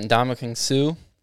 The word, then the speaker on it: Ndamukong Suh: Yeah, we've got Ndamukong Suh.